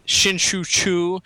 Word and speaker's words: Shin-Soo Choo: I know uh, Shin Shin-Soo Choo is a free agent. Um, that is the know, best pronunciation that we've had on the show so far tonight.